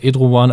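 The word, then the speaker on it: Erdoğan: Und das geht uns, glaube ich, sehr, äh, sehr verbreitet, dass wir jetzt überhaupt nicht wissen, was macht dieser Edrowan eigentlich, also so spricht man den Namen ja, glaube ich, jetzt aus, mit einem stummen G, äh, in seinem eigenen Land.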